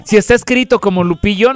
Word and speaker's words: Lupillo: Con doble L. Ajá. – Lupillo. – No con Y. Sí, porque luego hay quienes lo quieren pronunciar como Yupillo.